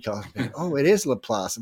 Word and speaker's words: Laplace: Well, I won like it was a $2,000 for somebody over Laplace because it's spelled L-A-P-L-A-E-C-E. It's Laplace. That's what it spelled like. I said, people, but it's French. French is Laplace.